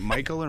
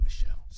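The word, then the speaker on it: Michel: Is it Michael or Michelle? – It's Michelle. – Michelle.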